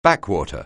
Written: backwater

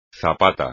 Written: zapata

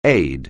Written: aid

